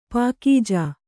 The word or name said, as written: ಪಾಕೀಜು